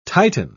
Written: titan

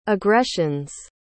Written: aggressions

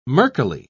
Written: murkily

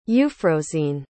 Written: euphrosyne

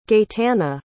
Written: Gaetana

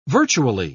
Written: virtually

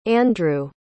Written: Andrew

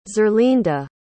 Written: zerlinda

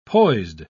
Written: poised

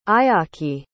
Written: ayaki

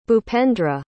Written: bhupendra

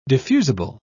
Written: diffusible

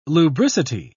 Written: lubricity

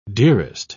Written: dearest

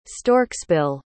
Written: storksbill

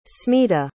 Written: smita

